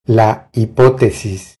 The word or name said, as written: La hipotesis